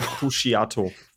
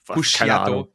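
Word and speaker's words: Puciato: Kak Greg Pusciato. – Pusciato.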